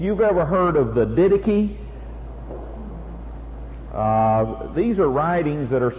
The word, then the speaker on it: Didache: D-I-D-A-C-H-E. Didache.